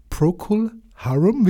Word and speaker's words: Procol Harum: Äh, Procol Harum, wie spricht man die richtig aus, bitte? Ich glaube, das ist korrekt, ja, ja?